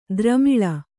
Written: ದ್ರಮಿಳ

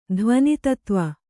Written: ಧ್ವನಿತತ್ತ್ವ

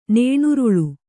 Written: ನೇಣುರುಳು

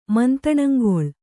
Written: ಮಂತಣಂಗೊಳು

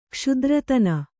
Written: ಕ್ಷುದ್ರತನ

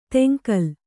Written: ತಂಗಾಲ

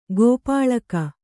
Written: ಕಾಪಾಳಿಕ

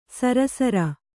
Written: ಶರಾಸಾರ

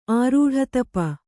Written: ಆರೂಢತಪ

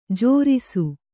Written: ಜೇರೈಸು

